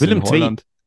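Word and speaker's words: Willem II: Bei Schalke war er eben, bei Willem Dö, glaube ich, spricht man es aus. – Willem Twee. Willem Twee.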